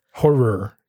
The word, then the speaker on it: horror: doesn't enunciate when they say the word horror? – Horror. Yes. – Horror. – It's two syllables, right?